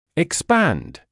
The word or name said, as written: expand